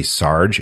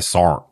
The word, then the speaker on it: Sarn: Or some like a modern military movie and somebody calls somebody Sarge and you're like, first of all, ain't nobody calling nobody Sarge. All right. Sarn. That's how you say it.